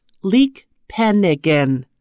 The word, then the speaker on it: leekpenigan: The Mi'kmaq word for basket is leekpenigan, pronounced leekpenigan.